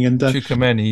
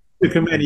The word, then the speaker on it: Tchouaméni: And, you know, that they've got the two guys in midfield, Rabiot and one that I can't pronounce his name, Tchouaméni or something. – Tchouaméni, yeah. – Tchouaméni, yeah.